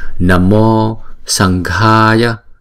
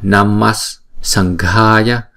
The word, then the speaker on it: Namas Sanghaya: Nhưng mà chúng ta có thể đọc là Namas Sanghaya, bởi vì theo cái luật của tiếng Phạn đó, cái chữ Namaha sẽ được đổi thành chữ Namo, hay là nếu mà theo sau đó là chữ S thì chúng ta sẽ phát âm là Namas, Namas, Namas Sanghaya.